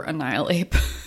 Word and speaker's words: Annihilape: I mean, I was just determined that people pronounce it correctly. Don't keep adding in this extra N. – Yeah. I do adore Annihilape.